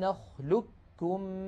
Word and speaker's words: نخلقكم: اجتمع في هذه الكلمة قاف ساكنة بعدها كاف. فكل الطرق عن حفص تقلب هذه القاف كافا طبعا ساكنة، وتدغم الكاف في الكاف فيصير النطق بكاف مشددة هكذا، ألم نخلقكم من ماء مهين.